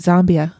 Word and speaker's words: Zambia: Now, you said Zambia, right? Did I say that right? – Yes, you did say it right. – I always called it Zambia.